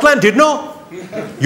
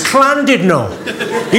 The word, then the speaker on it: Llandudno: This guy, front and centre, where you're sitting there, face like fucking thunder, went, it's not Llandudno, you bloody fool. It's Llandudno. In Wales, the double L is pronounced with a C.